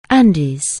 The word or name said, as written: Andes